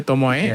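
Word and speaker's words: tomoi: So, I mean, that's how it's pronounced here, tomoy.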